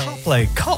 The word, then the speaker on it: Coldplay: It's like I'm falling in love, la canzone Coldplay. Dillo tu Coldplay. Perché rischieresti di dire Coldplay. – No, Coldplay. – No, Coldplay.